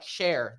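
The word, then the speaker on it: Cher: But I just realized when I Googled it for this podcast, it's spelled like share, the singer. – Yeah. – C-H-E-R. I thought it was just S-H-A.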